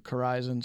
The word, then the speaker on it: Corizon: Their major contract is with a company, I'm not quite sure how it's pronounced, but Corizon, C-O-R-I-Z-O-N, like Horizon.